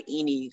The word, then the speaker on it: Ini: for me, like, okay, I go by Ini because, that's usually easier for people to pronounce and shorter.